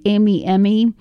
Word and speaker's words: Emimemi: So we're going to start off with a Japanese Australian act that I just came across this week, and I think it's pronounced Emi Emi, E-M-I-M-E-M-I, and Tabaro is the first song.